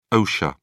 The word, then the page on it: osha: British English